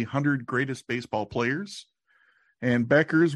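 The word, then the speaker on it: Bueckers: That is kind of how it's spelled, but the correct answer is Satchel Paige Beckers. But yes, it is spelled with that U in there.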